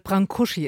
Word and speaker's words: Brâncuși: Ich habe es vernuschelt, du hast es vernuschelt. Brâncuși ist die richtige Aussprache.